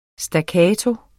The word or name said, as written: staccato